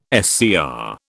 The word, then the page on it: SCR: British English